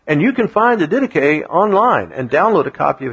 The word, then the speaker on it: Didache: It looks like it should be pronounced Didache, but it's Didache.